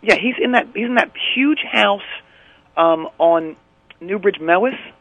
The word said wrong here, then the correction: Mews: M-E-W-S. – Muse? – Is that how you say it? – Newbridge M-E-W-S. Muse. – I've been saying it Mewis for my entire life. – Well, it's Muse.